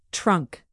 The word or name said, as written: trunk